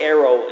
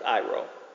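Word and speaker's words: Iro (αἴρω): The word take is the word arrow in the Greek. A-I-R-O. Actually, it's pronounced Iro in the Greek.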